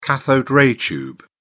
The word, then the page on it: cathode-ray tube: British English